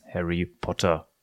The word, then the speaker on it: Harry Potter: Es ist so, äh, Harry Potter wird auch im Bulgarischen als Harry Potter ausgesprochen.